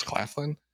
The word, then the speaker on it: Claflin: Yes, Claflin.